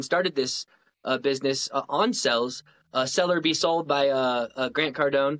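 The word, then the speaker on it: Sell or Be Sold: And it's funny, he doesn't pronounce it D's. He says Seller Be Sold. And so that's anytime I say the name of the book, you got to say it like he says it, because he says Seller Be Sold. Like he says the whole line.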